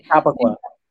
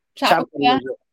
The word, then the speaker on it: Chappaqua: Chappaqua. – Chappaqua, New York.